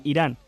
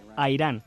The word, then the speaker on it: Irán: Nuestro país se pronuncia Irán, no a Irán.